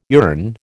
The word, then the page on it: yearn: American English